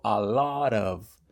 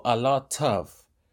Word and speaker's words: a lot of: Bueno, yo tengo una pronunciación americana y yo digo a lot of. No digo a lot of. Mucho ojo, si están en Estados Unidos, a lot of, como si fuera una D, a lot of.